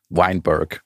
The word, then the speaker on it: Weinberg: Weiß ja, nein, Jay Weinberg schreibt man ihn. Ich glaube, spricht man ihn dann Weinberg aus? Weinberg. Weinberg. Ja, Jay Weinberg.